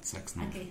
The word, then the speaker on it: Zack Snyder: Sean bienvenidos a este episodio número 21 del podcast más escuchado por el millón, por los millones de hijos que tiene Jack Snyder. ¿Sí lo dije bien? Zack Snyder.